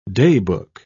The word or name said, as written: daybook